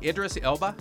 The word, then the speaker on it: Idris Elba: Is it Idris Elba? Is that how you pronounce that dude's name? – I do, yeah.